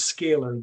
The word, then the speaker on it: scalar: on reducing stress and anxiety using Sacular light. And I hope yes. I pronounced that correctly. – Yes. Thank, thank you so much. we will refer to it as scalar.